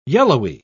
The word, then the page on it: yellowy: British English